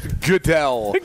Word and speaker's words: Goodell: There's no way he Goodell. – Can't know that his name is pronounced Goodell.